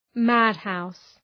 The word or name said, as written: madhouse